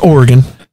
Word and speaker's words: Oregon: It's pronounced Oregon. You're not from California. You're from South Alabama.